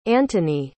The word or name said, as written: antoni